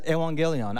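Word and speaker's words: euangelion: The gospel in biblical Greek is pronounced as evangelion.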